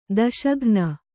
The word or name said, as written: ದೋಷಘ್ನ